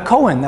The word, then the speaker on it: Kohen: A Kohen, that's correct. You could spell that K-O-H-E-N. Sometimes as a last name, it's spelled C-O-H-E-N, as in the case of, let's say, Leonard Cohen.